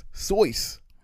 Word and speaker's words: Seuss: And Seuss isn't even how you pronounce S-E-U-S-S. It's actually supposed to be pronounced Soyce.